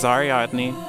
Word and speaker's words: Ariadne: Ariadne? Is that how you say it? – This is Ariadne.